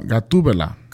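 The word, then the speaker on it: Gatúbela: Is it my pronouncing? Guatabella. – No, Gatubela.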